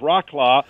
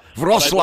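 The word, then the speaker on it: Wrocław: It looks like it should be pronounced Rocklaw. – Wroclaw. – I believe it's Wroclaw.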